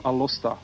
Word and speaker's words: Alosta: It's Ondine from Alosta. So Alosta is the kind of, let's call it the street name for Aalst. – Yeah, that's correct. – And so it's spelled A-L-O-S-T-A.